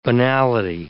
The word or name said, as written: banality